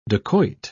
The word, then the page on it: dakoit: British English